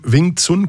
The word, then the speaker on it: Wing Chun: Das bespreche ich jetzt mit dem Wing Chun Kung Fu Meister Christian Zitzke.